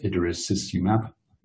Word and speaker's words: idarucizumab: So you might be aware there are reversal agents for dabigatran, a drug called idarucizumab, that's how you pronounce it, and also a reversal agent for the factor 10A drugs, rivaroxaban, edoxaban, and apixaban, and dexanet alpha.